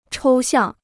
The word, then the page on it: 抽象: chōu xiàng